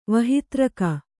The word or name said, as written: ವಹಿತ್ರಕ